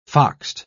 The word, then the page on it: foxed: British English